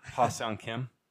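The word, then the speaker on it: Ha-seong Kim: Hong Sung Kim. Ha Sung? I don't know how to say it. Kim. – Yeah, Ha Sung Kim.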